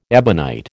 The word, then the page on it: ebonite: American English